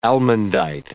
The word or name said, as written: almandite